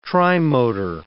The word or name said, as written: trimotor